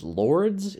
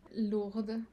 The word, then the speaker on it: Lourdes: And Lords, is that how you pronounce the last one? – Lourdes.